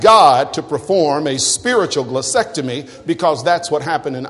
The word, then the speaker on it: glossectomy: Number one, I want you to ask God for a spiritual glossectomy. And I'm going to spell it for you. Somebody said, is that in the dictionary? Yeah, it's in the dictionary. G-L-O-S-S-E-C-T-O-M-Y. Glossectomy.